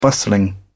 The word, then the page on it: bustling: British English